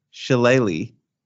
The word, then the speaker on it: shillelagh: It is pronounced shillelagh. I was very off base.